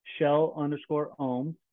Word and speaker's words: Kjell Ooms: Instagram handle is Shell_Ooms, but it's spelled funny. So KJELL_OOMS.